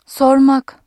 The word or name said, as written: sor-